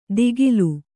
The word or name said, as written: ದಿಗಿಲು